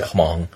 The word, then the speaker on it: Hmong: I read one book about the Hmong that's spelled with an H. – Yeah. – Hmong.